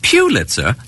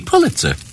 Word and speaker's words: Pulitzer: Although many people pronounce it Pulitzer, the correct pronunciation is actually Pulitzer.